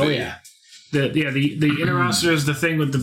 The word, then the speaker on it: Interocitor: Yeah, the Intercerator. How do they pronounce it? – Interocitor. – No, the Interocitor that he has in both the films and the video, Dare to Be Stupid, and his film, UHF.